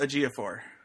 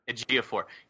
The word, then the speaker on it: Ejiofor: Chiwetel Ejiofor. I hope I'm not butchering it. – Chiwetel Ejiofor. – Ejiofor.